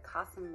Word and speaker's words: Qasim: There's a reason that Qasim rhymes with awesome, because he's absolutely awesome at Google Ads.